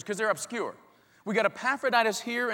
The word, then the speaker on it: Epaphroditus: Epaphroditus. We had a fun time with this name. There's a lot of great words that rhyme with Epaphroditus, like laryngitis and hepatitis.